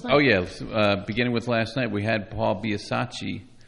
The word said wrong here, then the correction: Biasaccia: I always get his name wrong. It's Biasaccia, Jennifer.